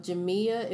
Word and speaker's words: Jemea: It's by a young lady by the name of Jamia, if I'm not mistaken. That's how you pronounce her first name, J-E-M-E-A, Kingsby Jackson.